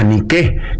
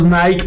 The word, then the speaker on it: Nike: Representa a Nike, que es la diosa griega de la victoria. – O sea, que nosotros le decimos Nike por la marca. Sí. Pero se pronuncia Nike.